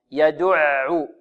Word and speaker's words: يدع: الحروف المستفلة المرققة، لابد من ترقيقها، يدع، يدع، ولابد من إعطائها توسطها، يدع، لا نقول يدع. فذلك الذي يدع اليتيم، يدع اليتيم خطأ، لابد من نطقها هكذا، يدع، مثل أر وأل أع أم، هذه كل حروف متوسطة، تعطيها زمن، زمنا متوسطا.